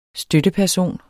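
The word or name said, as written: støtteperson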